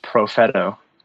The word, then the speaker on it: Profetto: It's very Sicilian. – Profeta? – It is Profeta.